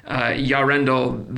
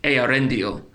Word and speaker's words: Eärendil: So specifically this comes up because every time I hear you guys talk about Yarendel the Mariner, in my own mind and based on this, my pronunciation would have been something more like Eärendil, with the Ea, the same way that you pronounce the name of the world of creation, Ea. The E and the A sound should not be lumped together based on what I'm reading here.